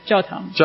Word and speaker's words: 教堂: But the two words are, jiao tang? How do you say that? – Yeah, 教堂。